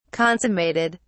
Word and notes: consummated: British English

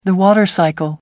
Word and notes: water cycle: British English

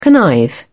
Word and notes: connive: British English

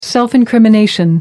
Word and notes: self-incrimination: American English

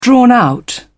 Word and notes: drawn-out: British English

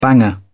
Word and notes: banger: British English

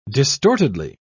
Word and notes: distortedly: British English

